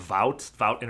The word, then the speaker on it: Vought: They're basically backed by like a corporation called Vought International.